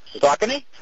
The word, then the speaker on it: Saucony: And I got, I can't never pronounce it right, Saucony? – Saucony. – Saucony. See, that doesn't look like the way it should be pronounced.